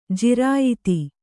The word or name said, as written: ಜಿರಾಯತ